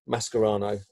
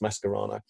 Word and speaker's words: Mascherano: that people don't understand is why Mascherano, but actually it's pronounced Mascherano, wasn't played or whatever.